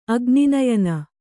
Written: ಅಗ್ನಿನಯನ

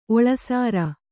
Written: ಒಳಸಾರ

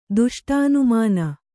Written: ದುಷ್ಟಾನುಮಾನ

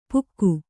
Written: ಪುಕ್ಕು

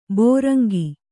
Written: ಭೃಂಗಿ

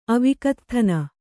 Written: ಅವಿಕತ್ಥನ

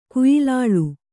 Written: ಕೊಯ್ಲಾಳಿ

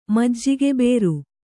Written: ಮಜ್ಜಿಗೆಬೇರು

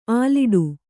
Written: ಅಲ್ಲಡ

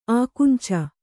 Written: ಆಕುಂಚ

